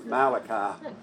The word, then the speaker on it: Malachi: I thought Malachi was Malachi. I thought, is that dude Italian or something? But no, it's Malachi. It's just the way you pronounce the book.